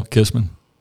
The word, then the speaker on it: Kissmann: Kyle Kissmann. – Just like it's spelled. Sounds just like it's spelled. – Two N's.